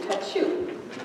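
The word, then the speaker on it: tachou (ταχύ): And to me, it sounded, when I first tried to figure out how to say this properly, it sounded like they needed a tissue, tachou.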